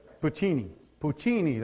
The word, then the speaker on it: Puccini: There's a famous composer, and we'll try to pronounce his name. It's Italian, Giacomo Puccini. Puccini, that's it.